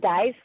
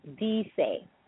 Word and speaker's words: dice: Okay, it's not dice like in English, but the I is like a double E as in need, and the E is like E as in elephant or egg, so that's dice.